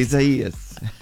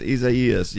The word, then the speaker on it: Isaias: Isaias. – You can tell I haven't been listening to the weather much. – It's not Isaias. It's Isaias. Yeah, okay.